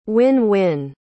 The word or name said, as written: win-win